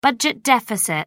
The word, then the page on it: budget deficit: British English